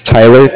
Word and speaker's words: Tyler: My name is Tyler, T-Y-L-E-R, and I'm calling with Cardiff. It's spelled C-A-R. D-I-F-F, as in Frank.